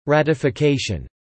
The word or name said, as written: ratification